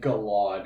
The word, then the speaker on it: Galad: I know it's how it's supposed to be pronounced, but I'm gonna beg you to stop saying Galad, because it's gonna fucking kill me. – It sounds like salad, and he'd be a really plain-ass salad.